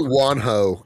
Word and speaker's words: Juanjo: Is it, do you think it's Juanjo or Juanjo Guarnido? – How is it spelled? – It's spelled J-U-A-N-J-O. – Definitely Juanjo, because it's Spanish.